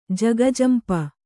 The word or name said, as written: ಜಗಜಂಪು